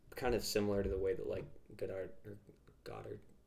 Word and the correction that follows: Godard: Jean-Luc Godard? – Yeah, I don't know.